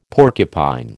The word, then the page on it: porcupine: American English